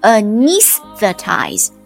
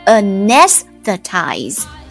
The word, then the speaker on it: anesthetize: 我们来看读音哦，读音它有两种，英式读的话是 anesthetize，第二个读音呢 anesthetize。